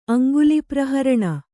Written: ಅಂಗುಲಿಪ್ರಹರಣ

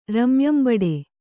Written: ರಮ್ಯಂಬಡೆ

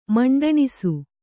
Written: ಮಂಡಣಿಸು

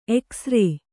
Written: ಱ

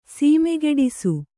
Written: ಸೊಮ್ಮುಗಿಡಿಸು

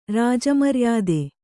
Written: ರಾಜಮರ್ಯಾದೆ

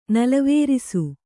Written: ನಲವೇರಿಸು

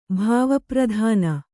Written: ಭಾವಪ್ರಧಾನ

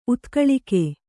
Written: ಉತ್ಕಲಿಕೆ

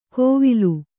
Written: ಕೋವಿಲು

